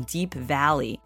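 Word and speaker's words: Deap Vally: Coming up, I've got the song Royal Jelly from Deep Valley, and that's spelled D-E-A-P Valley, V-A-L-L-Y.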